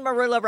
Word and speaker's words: marula: Now, am I saying marula right? – You're saying it right, and I have a very funny story for you that I think your listeners will enjoy.